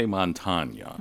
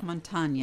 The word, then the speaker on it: Montagna: I say Montagna. – Montagna, yeah, like lasagna.